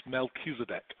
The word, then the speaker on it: Melchizedek: And, you know, the Melchizedek, by the way, it's pronounced Melchizedek. – Sorry, you're close.